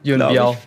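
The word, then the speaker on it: Yuen Biao: mit Samhung und Yuan Biu. – Yuan Biao. Biao. – Biao spricht man es aus.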